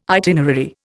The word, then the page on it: itinerary: Indian